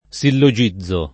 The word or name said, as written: sillogizzare